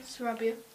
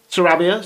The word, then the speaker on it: Sarabia: Uh, we had, uh, we had, how do you pronounce it? Sarabia, did you say? – Sarabia. – Sarabia, sorry.